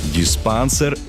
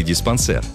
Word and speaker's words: диспансер: Диспансер или диспансер? Где ударение? Многим так и хочется поставить его на второй слог, на «а». Но правильный вариант – «диспансер».